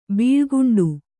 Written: ಬೆಳಿಕೊಂಡೆ

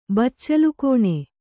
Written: ಬಚ್ಚಲುಗೋಣಿ

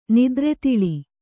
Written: ನಿದ್ರೆತಿಳಿ

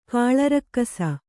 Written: ಕಾಳರಕ್ಕಸ

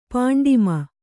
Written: ಪಾಂಡಿಮ

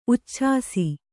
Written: ಉಚ್ಛಾಸಿ

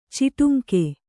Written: ಚಿಟುಂಕು

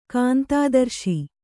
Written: ಕಾಂತಾದರ್ಶಿ

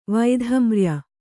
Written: ವೈಧಮ್ರ್ಯ

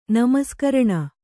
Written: ನಮಸ್ಕರಣ

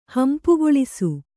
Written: ಹಂಪುಗೊಳಿಸು

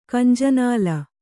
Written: ಕಂಜನಾಲ

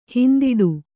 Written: ಹಿಂದಡಿ

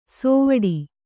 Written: ಸೋವಡಿ

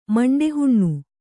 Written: ಮಂಡೆಹುಣ್ಣು

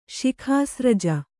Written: ಶಿಖಾಸ್ರಜ

